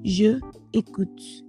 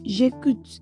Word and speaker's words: j'écoute: In French language, two vowel sounds can stay next to each other. Whenever it happens, it is replaced with an apostrophe, which is why we do not say je écoute. Instead, we say j'écoute